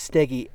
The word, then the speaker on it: Steege: So first of all, it looks like it should be pronounced Stege Hill. It's S-T-E-E-G-E. And I found out that the proper pronunciation is Steggy after calling it Stege Hill for about an hour in a presentation.